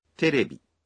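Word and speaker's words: تیریبی (テレビ): انگریزی لفظ ٹیلی ویژن جاپانی میں اس طرح سے کہا جاتا ہے تیریبی،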